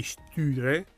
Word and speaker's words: Sture: per poi arrivare agli omicidi degli Stüre. Stüre? Si scrive Sture, ma siccome sono di Uppsala, se io ci immagino la dieresi.